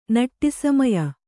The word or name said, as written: ನಟ್ಟಿಸಮಯ